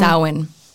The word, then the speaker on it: Samhain: Samhain. It's a really, I looked this up and my friend reminds me. Yeah, it's a really interesting, pronunciation to spelling.